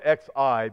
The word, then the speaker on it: Xi Jinping: You spell it X-I, but I think Xi is how you pronounce it.